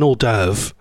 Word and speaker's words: hors d'oeuvre: Oh, by the way, not hors d'oeuvres. It's called an hors d'oeuvre.